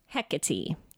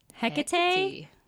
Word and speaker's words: Hecate: These are the ways you pronounce it. Hecate, Hecate, Hecate, Hecate, or Hecate. – So just kind of choose whichever one you like, I guess. – Yes. So the Greek pronunciation is either Hecate or Hecate with a stressed syllable in the center. The British pronunciation is either Hecate or Hecate with the stressed first syllable, very common in English.